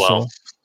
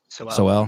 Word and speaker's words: Sowell: His name is, What Soul's for Thomas Soul? Am I saying his name right? Thomas Sowell. – Sowell.